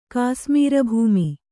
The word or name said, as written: ಕಾಸ್ಮೀರಭೂಮಿ